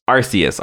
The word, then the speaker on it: Arceus: How is it pronounced? What's the official? – Arceus. – Arceus. Was not close.